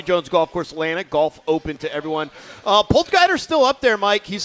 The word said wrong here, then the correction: Potgieter: It's pronounced Potgieter. – Potgieter.